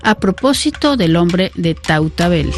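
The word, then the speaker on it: Tautavel: Pues Amélie, muchísimas gracias por esta, por este reportaje aquí en el Museo del Hombre, para hablar de este hombre, yo lo pronuncio al español, del hombre de Tautabel.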